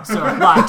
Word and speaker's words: sol: Yeah, it's S-O-L, but no one says the L even when they pronounce it.